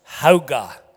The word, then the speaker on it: Hauge: It's spelled H-A-U-G-E, but pronounced Hauga.